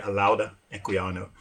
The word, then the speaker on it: Olaudah Equiano: I mean, Olaudah Equiano or Olaudah Equiano, depends on who's pronouncing it. I'm not actually from West Africa, so I could be pronouncing it wrong, but most people say Olaudah Equiano.